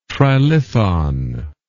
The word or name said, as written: trilithon